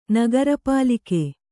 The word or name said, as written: ನಗರಪಾಲಿಕೆ